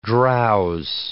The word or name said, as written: drowse